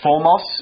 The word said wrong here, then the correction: 福尔摩斯: 福尔摩斯。 – 福尔摩斯, yes.